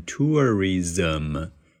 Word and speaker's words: tourism: 呃，因此呢，我就告诉你一个窍门啊，窍门就是呢，在现实生活中，in real life，外国人呢会把它读成 ism，ism，那个 z 和 m 那里呢加一个 a 的音，短音 a，不是长音 er 哈，所以呢，我们就读成 tourism。